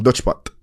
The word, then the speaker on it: Dutch Pot: Dutch Pot. – Dutchback. – Dutch pot. Like pot. Netherlands. – I'm going to get you to spell it for the viewers. – D-U-T-C-H-P-O-T.